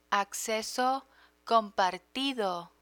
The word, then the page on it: acceso compartido: Spanish